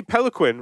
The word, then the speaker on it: Peloquin: So, uh, Joey Peloquin, right? Did I say that right? That's correct. Yeah.